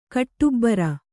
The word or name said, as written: ಕಟ್ಟುಬ್ಬರ